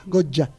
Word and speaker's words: Goya: Goya, no Goya, Goya. Pronunciado así, esa Y pronunciada a lo correntino.